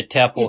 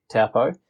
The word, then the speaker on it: Taupo: But it's same with Taupo. I think it's just become the norm for non-New Zealanders to pronounce it incorrectly. – Yeah. But even us down south, we'll say Taupo. But it's really? – It's Taupo. – Yeah. Oh, shivers. So do you get frowned upon being a New Zealander and still saying it incorrectly? – Well, most New Zealanders probably say it incorrectly, so it depends.